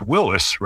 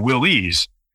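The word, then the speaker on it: Willys: But of course, the man behind the name was John North Willis, and the name was properly pronounced Willis rather than Willie's.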